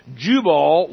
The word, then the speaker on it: Jubal: J-U-B-A-L. Jubal, Jubal, however you want to. How do you say it? Either way.